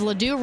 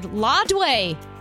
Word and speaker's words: Ladue: My GPS calls Ladue Road Lodway.